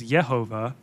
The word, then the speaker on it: Yehovah: Or more specifically, or it's like the way it's technically pronounced is Yehovah, but the Jehovah thing,